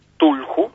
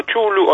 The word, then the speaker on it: Cthulhu: Es una palabra que se escribe C-T-H-U-L-H-U. Tulhu, ¿no? Y ahí cada uno, algunos prefieren llamarlo Chulu, otros Cthulhu, bueno, cada uno, sí.